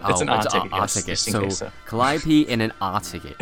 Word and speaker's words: Calliope: Is that who's saying Calliope.